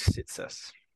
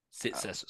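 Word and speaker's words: Tsatas: How do I say that last name that you're saying? – That one is Sitsis. – Sitsis. So Elijah Sitsis.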